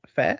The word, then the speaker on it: Fehr: Fair, I think that's how you say his name.